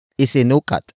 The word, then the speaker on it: isinukat: Isinukat ko ang uniforme. Isinukat ko ang uniforme. The first word is isinukat. Isinukat. Isinukat. Isinukat is spelled as I-S-I-N-U-K-A-T.